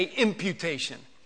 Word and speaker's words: imputation: Say imputation. You know how you say it? You imp, imp, I-M-P. It doesn't have anything to do with this word. But I-M-P-U-T-A-T-I-O-N, imputation.